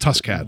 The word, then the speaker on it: Tuskcat: It's it's spelled T U S K C A T, so it's not like Tusken, like Tusken oh, Raider. Oh, okay. – It's just it's just tuscat.